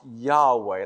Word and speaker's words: Yahweh: Now, in the Hebrew language, I am is most likely pronounced Yahweh,